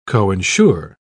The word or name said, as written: coinsure